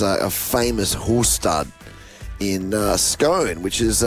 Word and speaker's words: Scone: I'm in Scone. – Scone. – And I'm not saying that just being fancy. It's how you say it. – Yeah. – Scone. – Not Scone.